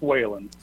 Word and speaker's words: Wayland: And just a quick note, my last name is pronounced Wayland. – Wayland, all right, like the city Wayland. Very good. – But no D, that's me.